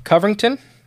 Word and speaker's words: Covington: The same day, less than 5 miles away in Covington, I think that's how it's said.